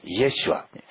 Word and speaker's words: イエシュア: イエシュア。イエス様はイエシュアというヘブライ語発音です。